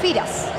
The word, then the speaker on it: Firas: Se llama Salen, no, se, no llama, se llama Feras. – Firas Al Burakian. – Pero se escribe Feras, sí. ¿No? – Sí. Firas.